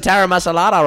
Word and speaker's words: taramasalata: This is some taramasalata or whatever. Did I pronounce that right? – Yeah, you did.